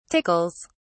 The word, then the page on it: tickles: British English